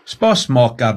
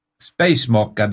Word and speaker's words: Spasmaker: Spasmaker du? – Spasmaker? – Nei, S-P-A-S. – Ja. – M-A-K-E-R. Spacemaker, nei, det skal uttales på norsk, spasmaker.